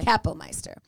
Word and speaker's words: Kapellmeister: And I think it's Kapellmeister. I think the emphasis is on the ka. – Okay. – Kapellmeister.